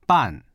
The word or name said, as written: ban4